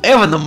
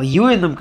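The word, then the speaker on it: Ewan: как бы просто как-то в детстве он был Эваном Макгрегором по телевизору, но потом он стал Юэном, когда все немножко английский язык выучили и поняли, как шотландские имена произносятся.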